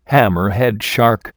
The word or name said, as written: hammerhead shark